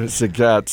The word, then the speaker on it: Zagat: Zagat rhymes with the cat. – Zagat, sorry.